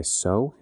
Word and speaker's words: Seow: The one that's made me think a lot about Ecclesiastes is by Sow. It's spelled S-E-O-W.